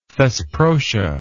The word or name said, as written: thesprotia